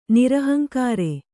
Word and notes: ನಿರಹಂಕಾರ: nirahaŋkāra

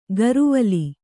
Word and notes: ಗರುವಲಿ: garuvali

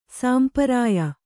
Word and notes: ಸಂಪ್ರಿಯ: sampriya; kannada

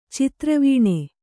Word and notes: ಚದುರೆವೆಣ್: cadureveṇ

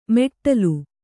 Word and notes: ಮೆಟ್ಟಲು: meṭṭalu

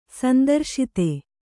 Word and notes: ಸಂತ್ರಸ್ತ: santrasta

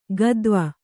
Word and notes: ಗದ್ವ: gadva

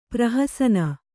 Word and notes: ಪ್ರಹಸನ: prahasana

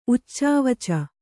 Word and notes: ಉಚ್ಚಾವಚ: kannada; uccāvaca

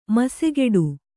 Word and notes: ಮೀಸೆಗಡಿ: mīsegaḍi

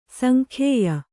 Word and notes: ಸಂಖ್ಯೇಯ: sankhyēya

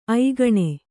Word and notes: ಐಗೇಣು: aigēṇu

